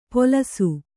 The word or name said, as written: ಪೊಲಸು